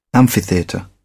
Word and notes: amphitheatre: British English

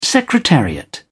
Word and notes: secretariat: British English

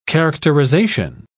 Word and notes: characterisation: British English

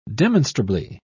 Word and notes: demonstrably: British English

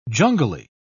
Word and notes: jungly: British English

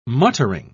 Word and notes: muttering: British English

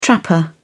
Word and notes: trapper: British English

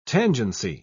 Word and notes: tangency: British English

